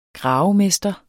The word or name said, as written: gravemester